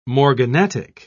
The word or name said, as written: morganatic